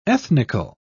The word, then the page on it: ethnical: British English